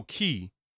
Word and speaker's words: Cay: Some people say Coco Key. Like when, I don't know. It's spelled C-A-Y, so I'm like, I'm going to keep saying K. – I think it's K.